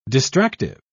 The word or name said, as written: distractive